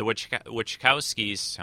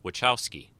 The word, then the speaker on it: Wachowski: I know how to say it. It's Wachowski. It's just Wachowski, that's all.